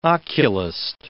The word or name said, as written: oculist